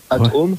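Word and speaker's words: At(h)ome: Pour ceux qui ne connaissent pas, ça s'écrit AT, ensuite parenthèse H, fermez la parenthèse OME, Atom.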